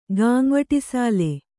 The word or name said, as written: ಗಾಂವಟಿಸಾಲೆ